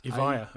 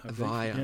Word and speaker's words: Evija: How do you say that, Any Lotus Evija. Evija.